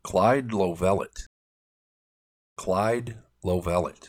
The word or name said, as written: Clyde Lovellette